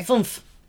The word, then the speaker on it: fünf: But wait, how do you say five in German? Sorry. – Fünf. – Fünf? – Fünf. – Fünf. – Fünf. – Fünf. Like Fumper from Bumby? Eins, zwei, vier, fünf.